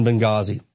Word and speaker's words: Benghazi: The H is silent in Benghazi.